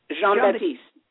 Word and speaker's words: Jean-Baptiste: Jean-Baptiste.